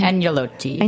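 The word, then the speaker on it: agnolotti: The menu is filled with seasonal antipasti, beautiful fish, and luscious pastas, including a transcendent sheep milk cheese-filled Anglo-Nati, how do you pronounce it? – Angelotti.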